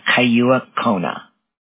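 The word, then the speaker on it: Kailua-Kona: Kailua Kona.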